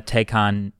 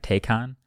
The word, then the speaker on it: Taycan: I'm sure if you put the Model S and the Taycan Turbo S next to each other, am I saying this right? Taycan? Taycan? – I'm not totally sure.